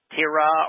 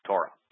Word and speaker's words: Torah: Did you pronounce it as Torah? – I did indeed. – Does anybody in the world that's going to say, no, it's not Torah, it's Tira or Tura or Tara,